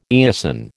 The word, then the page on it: eosin: American English